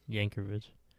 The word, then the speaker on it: Yankovic: How do you say it? – Yankovic.